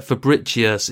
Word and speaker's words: Fabricius: We also have Helen Fabricius, and she's put in brackets rather helpfully that Fabricius is pronounced like fabulous and delicious, which I'd have actually guessed was the case anyway.